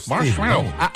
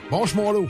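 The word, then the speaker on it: Marshmello: La preuve que vous êtes au meilleur de la musique, c'est Marshmallow, comme ça qu'on le prononce. – Ouais, tu l'as bien, ouais. – Marshmallow. – Marshmallow.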